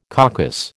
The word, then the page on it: caucus: American English